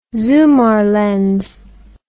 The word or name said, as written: zoomar lens